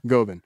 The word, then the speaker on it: Govan: Did I say that right? – Uh-huh. – Okay, Glasgow Govan. – Not S. Glasgow. – Glasgow. – There you go. – Govan.